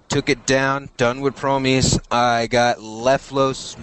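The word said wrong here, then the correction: Lufaise: Luface Meadows. Yeah. – Luface Meadows.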